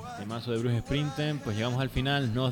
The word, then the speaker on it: Bruce Springsteen: Pues nos vamos a ir con una canción que le encanta a Jesús Morales, de Bruce Springsteen.